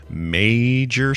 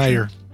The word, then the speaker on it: Meijer: says Amber Warwick of uh, Southfield says she bought the sealed Earthbound Farm spinach package earlier this week from a major store. Is that how you'd say that? – Major? – Meijer. Meijer. Oh, just Meijer, the J silent. – Meijer. – Yeah, the J is silent.